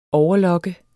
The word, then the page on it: overlocke: ˈɒwʌˌlʌgə; ˈɔwvʌˌlʌgə